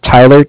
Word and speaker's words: Tyler: My name is Tyler, T-Y-L-E-R. And I'm calling from Bank of Cardiff. It's spelled C-A-R-D-I-F-F, as in Frank Frank.